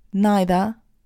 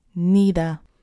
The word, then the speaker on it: neither: So there are two ways of pronouncing this word, neither or neither. You can use both, there is no change in meaning. One is British, neither. The other is American, neither.